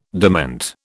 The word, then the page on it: dement: British English